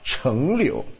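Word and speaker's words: 柽柳: 这个字应该是念成吧，一个木字边一个成圣的圣啊，成柳，英文名字叫做 tamarisk。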